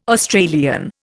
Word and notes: Australian: Indian

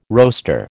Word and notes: roaster: American English

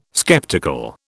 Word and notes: sceptical: British English

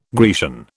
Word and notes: Grecian: British English

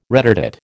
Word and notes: retardate: American English

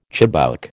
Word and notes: chibouk: American English

